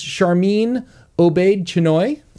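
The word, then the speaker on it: Sharmeen Obaid-Chinoy: No, I believe it's Charmin Obaid-Chinoy. – That's exactly how you pronounce her name.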